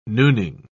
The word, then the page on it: nooning: British English